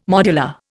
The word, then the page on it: modular: Indian